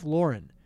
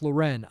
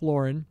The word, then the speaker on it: Lauren: By the way, it's Ralph Lauren. It's not Ralph Lauren. I've been saying it wrong my entire life.